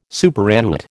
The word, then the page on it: superannuate: American English